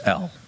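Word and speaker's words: L (אל): The word God in the Hebrew is two letters. It's E and an L, pronounced L. And it means spirit being. That's what it means. El.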